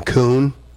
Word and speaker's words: Kuhn: I call him Kuhn, like the raccoon, but it's spelled K-U-H-N.